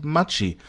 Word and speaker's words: Maggi: Warum zur Hölle wird, äh, wird dieses Gewürz Maggi ausgesprochen? Das nennt sich Machi.